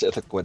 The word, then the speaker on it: Ithaqua: I don't think that they would pronounce Ithaqua differently. – Mm-hmm. So Derleth created Ithaqua, which is how I pronounce it, emphasis on second syllable.